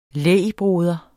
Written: lægbroder

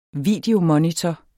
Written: videomonitor

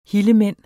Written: hillemænd

